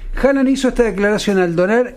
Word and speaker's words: Haaland: Escucha, Erling Haaland, que ayer nos decía en noruego se pronuncia Haaland. Pero bueno, vamos a decirle Haaland.